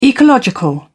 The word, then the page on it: ecological: British English